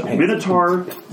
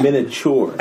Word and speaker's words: Minotaur: It's pronounced Minotaur. Minotaur.